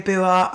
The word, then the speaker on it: Ebuwa: Hi, my name's Valerie Ebuwa.